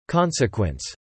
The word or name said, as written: consequence